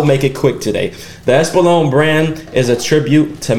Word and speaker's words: Espolón: All right, so I hope I say this right. The Espolón? Did I say that right? – Espolón. – Espolón.